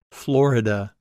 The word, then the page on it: Florida: English